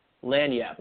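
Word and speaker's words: lagniappe: Everything is, as we always say, a little lamp day. What is that? Is that how they say it, Rob? Lanyap. Okay, I tried.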